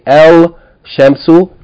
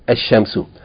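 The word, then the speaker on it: Ashamsu (الشمس): So you're not going to say El Shamsu. Rather, you're going to say Ashamsu.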